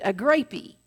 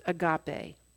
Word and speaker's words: agape: It was one of the translations of the Greek, and it's pronounced agape.